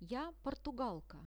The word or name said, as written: ja portugalka